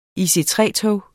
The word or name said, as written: IC3-tog